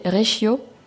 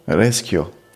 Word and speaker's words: Reschio: Il y a un hôtel qui s'appelle, comment le prononcer, Reggio. Je pense que c'est italien, donc je pense pas que c'est comme ça qu'on le prononce. – Reggio, peut-être? – R-E-S-C-H-I-O. – Reschio. – Reschio, peut-être. C'est un hôtel,